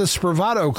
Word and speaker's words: Spravato: You're here to talk about the Spravato Clinic.